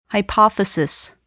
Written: hypothesis

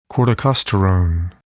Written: corticosterone

